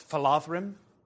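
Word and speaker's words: Falathrim: D-H is a voiced V sound, so that's phalathrim. Is how that's pronounced.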